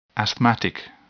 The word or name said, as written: asthmatic